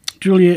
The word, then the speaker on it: Giulia: Giulia is the Italian version of Julia, and the name is pronounced as the same as the English spelling would be.